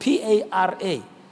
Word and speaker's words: para: the Hebrew word is the word P A R A, para.